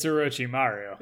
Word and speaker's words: Orochimaru: It's Orochimaru. – Yeah, I can't. – Right? It's Orochimaru.